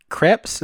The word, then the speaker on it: Krieps: Yeah, well, him and Vicky Krieps, is that how you pronounce her last name? – Yeah.